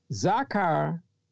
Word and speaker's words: zakar: Zakar, which is the word for remember, is spelled Z-A-K-A-R, Z-A-K-A-R, zakar.